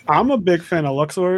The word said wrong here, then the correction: Luxord: It's Luxord.